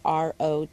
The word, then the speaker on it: Rowe: Last name is spelled Rowe, and then photography.